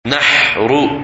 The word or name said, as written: Nahr